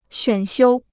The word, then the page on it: 选修: xuǎn xiū